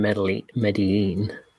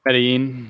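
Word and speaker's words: Medellín: I was on loan from Medellin. Oh my gosh, I'm butchering that. – Medellin, yeah.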